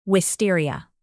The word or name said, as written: wisteria